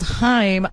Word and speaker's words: Haim: But it's pronounced Haim. – Well, Corey Haim's family chose to pronounce it Haim, but you can pronounce it either way. They choose Haim.